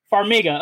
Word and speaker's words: Farmiga: I think they're, how do you pronounce her last name? Farmiga or something? Farmiga. Okay, so Tessa Farmiga.